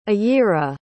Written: ayira

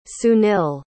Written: sunil

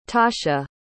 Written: tasha

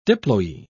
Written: diploe

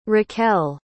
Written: raquelle

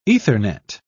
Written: ethernet

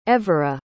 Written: evera

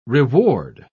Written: reward

